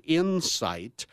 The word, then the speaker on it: Incyte: This one's called Insight. I-N-C-Y is a symbol, and it's spelled I-N-C-Y-T-E. – Okay. – So we're not trying to incite violence or anything like that.